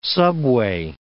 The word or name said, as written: subway